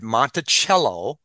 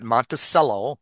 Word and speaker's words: Monticello: He arrived at 5 in the morning at Monticello, and that's how you pronounce it, not Monticello, Monticello, which is the estate for Thomas Jefferson.